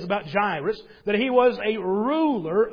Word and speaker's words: Jairus: And just so you know, being transparent with you this morning, I will want to continue calling him Jairus. But that's not how you say his name. There is no I after the R. It comes before the R. So it's Jairus.